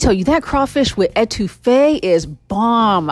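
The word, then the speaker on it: étouffée: We didn't stay in to eat inside, but we ended up getting crawfish with etouffee. That's how it's pronounced, but it's crawfish with etouffee. It's spelled E-T-O-U-F-F-E-E.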